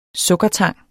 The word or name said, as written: sukkertang